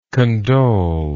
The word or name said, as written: condolingly